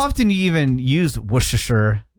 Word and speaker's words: Worcestershire: Worcestershire. Worcestershire. – It's very underrated. – That's the pronunciation. – Morgan? Worcestershire. – Worcestershire. – Worcestershire. So you don't say the R, you don't say Worcestershire? – No.